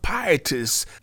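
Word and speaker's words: pietis (πίστις): It's a noun which is pronounced pietis, to have a firm, strong conviction, assurance, or persuasion that something is real, that something is true.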